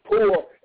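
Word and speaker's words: pur: it's P-U-R, but it's pronounced poor, amen.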